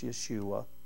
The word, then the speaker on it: Yeshua: And if you know about Scripture, we call him Jesus, but his name is the same as Joshua. It's Yeshua. That's how you would pronounce it.